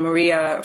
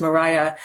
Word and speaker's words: Maria: Elizabeth and Maria, sometimes pronounced Mariah.